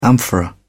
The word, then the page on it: amphora: British English